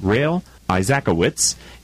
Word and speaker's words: Rael Isaacowitz: Our second guest is Rael Isaacowitz, and he is the founder of Bossy Pilates. Did I say that right, Rael? – That is correct.